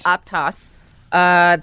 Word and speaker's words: Aptos: Aptos.